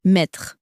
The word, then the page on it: mettre: french